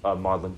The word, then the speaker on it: Magdalen: That's spelled Magdalen College. M-A-G-D-A-L-E-N. Magdalen, pronounced Magdalen. I have no idea why.